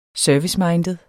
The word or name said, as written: serviceminded